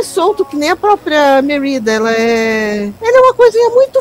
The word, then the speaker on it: Merida: Tá, o cabelo da Merida, eu fui atrás para ver a pronúncia, pelo menos em inglês, né? É Merida.